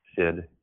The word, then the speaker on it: Syd: His name is spelled S-Y-D, Sid, M-E-A-D, Mead.